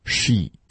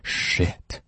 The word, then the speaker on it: Sheet: 还是第一组 Shoe Shoe Sheet Sheet 要注意发音呢，如果你把这个长音一一发成了短音，诶，那就变成了 Shit, that's a bad word.